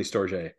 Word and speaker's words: StorJ: So to me, it's StorJ. But you know, if you talk to anybody outside the US, it's definitely StorJ. So, you know, you can sort of consider yourself on the avant-garde by pronouncing it StorJ. You know, you're one of the cool kids.